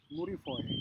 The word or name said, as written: muri foje